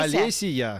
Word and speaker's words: Олесия: У тебя как-то получается как Олеся. Она не Олеся. – Олесия. – Олесия. Да.